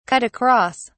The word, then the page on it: cut across: British English